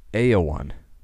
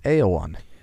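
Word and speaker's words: Éowyn: It's actually pronounced Eowyn. If you're going with the old English pronunciation, it's Aowyn.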